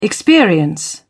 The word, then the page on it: experience: British English